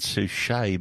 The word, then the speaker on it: Suchet: I would have said Suchet, but Suchet's probably right too. It ends in a T.